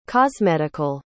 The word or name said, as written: cosmetical